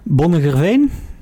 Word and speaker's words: Bonnegerveen: Enschede en Bonnegerveen. Ja, zeg ik het zo goed? Nee, dat klopt.